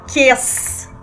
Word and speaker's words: Chies: É a Fernanda Kess.